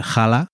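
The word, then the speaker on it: challah: Por Molly, que es eh, jala, que se escribe chala, por cierto, es en Yiddish.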